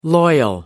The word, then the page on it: loyal: American English